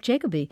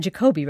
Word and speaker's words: Jacoby: But also others like conservative commentator Jeff Jacoby, who said, or Jacoby rather, he's a good friend, I can't believe I mispronounced that, who said that because Lewandowski signed a non-disclosure agreement with Donald Trump, he'll be unable to cover him.